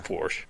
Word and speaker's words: Porsche: It's pronounced Porsche. – It is pronounced Porsche.